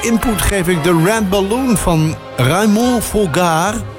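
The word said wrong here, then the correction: Froggatt: Raymond Froggert. Zo mag ik hem op zijn Amerikaans uitspreken.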